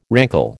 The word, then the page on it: rankle: American English